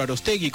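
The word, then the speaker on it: Aróstegui: A las 16:30 se presentará la obra de teatro Arostegui con acento en la O.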